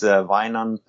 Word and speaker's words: Wijnand: But Vinard Berken, I don't know how you pronounce that. – Vinan Berken. – It sounds very Welsh, very Celtic. – He's Dutch. – He's Dutch. – So in Dutch, I think it is Wijnand Berken.